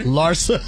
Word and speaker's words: Larsa: Larsa. That's how you say it.